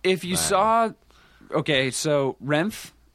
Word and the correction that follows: Refn: is that how you say his? I never know how to say his Rain. – Refn? I say Refn.